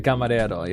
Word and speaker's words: camarero: Is that how you say it in Spanish? – That's it in Spanish. Camarero, yeah. For male waiters, yeah.